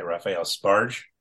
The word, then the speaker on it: Raphael Sbarge: It's directed by Raphael Sbarge.